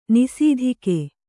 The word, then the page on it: ನಿಸಿದಿಗೆ: kannada